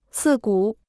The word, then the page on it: 刺骨: cì gǔ